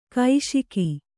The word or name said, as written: ಘೋಷಕ